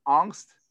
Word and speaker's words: Aungst: We have Pam Aungst.